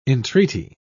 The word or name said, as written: entreaty